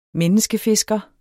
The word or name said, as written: menneskefisker